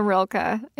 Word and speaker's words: Rilke: Rilke? Am I saying that right? You're the expert. – You have got Rainer Maria Rilke in Flora and Ulysses. Yes. Yes.